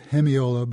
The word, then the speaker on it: hemiola: Yeah, hemiola is a funny word, sometimes pronounced hemiola, but anyhow, it's basically a rhythmical game that composers use, and Brahms loved to play this game more often than most composers.